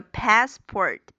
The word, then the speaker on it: passport: 还有就是美音中这个 passport 的读音，前面的这个 pass 原因是这个梅花，a 大口的梅花，a 嘴巴要很瘪。